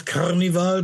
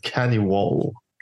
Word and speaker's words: Kaniwal: There's a small city in Western Tanizor called Carnival. Not Carnival, but K-A-N-I-W-A-L. – I pronounce that Cannibal. – Cannibal? – Yeah. – Yeah, it could be pronounced like Cannibal as well.